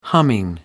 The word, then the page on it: humming: British English